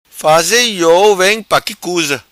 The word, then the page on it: Vem Faze Iou: patua